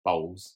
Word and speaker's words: bowls: So we played a game of balls. Is that how you say it? – Bowls. – Balls.